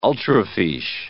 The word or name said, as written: ultrafiche